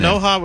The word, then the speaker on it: Sanoja: Javier Sanoja. How do you pronounce it? – Sanoja was right, but Javier.